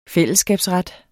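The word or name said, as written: fællesskabsretten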